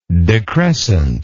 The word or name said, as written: decrescence